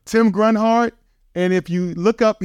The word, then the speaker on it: Grunhard: And if you can't pronounce his last name, just think about it. It's Tim G. Runhart. I believe when he was a young man, his high school coach was saying, Tim G. Runhart. – Runhart. – And that's how you spell it. Tim G. Grunhart. Tim Grunhart is Tim G. Runhart.